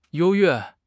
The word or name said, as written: 优越